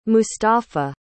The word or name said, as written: mustafa